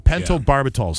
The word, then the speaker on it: Pentobarbital: They gave her two doses of the drug, the barbitol. Pentobarbital is how you say it.